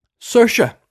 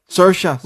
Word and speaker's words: Saoirse: Saoirse. Saoirse. – Rise with inertia.